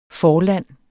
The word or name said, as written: forland